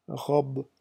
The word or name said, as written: robe